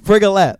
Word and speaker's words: Frigolette: Frigolette, like Gillette.